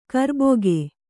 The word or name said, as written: ಕರ್ಬಕ್ಕಿ